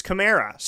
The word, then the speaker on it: Kamara: That there's Kamara and Kamara, and apparently it's Kamara. So we finally learned. He said, say it like a Camaro, but put an A at the end.